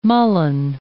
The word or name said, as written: mullein